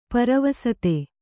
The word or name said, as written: ಪರವಸತೆ